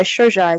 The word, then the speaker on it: Shojai: Is that the correct way to say it, Shojai? – Shojai is correct.